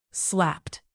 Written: slapped

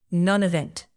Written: nonevent

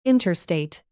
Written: interstate

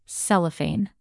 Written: cellophane

